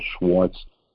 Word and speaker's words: Schwartz: The way to read about it is go to Zoom with Mel Schwartz dot com.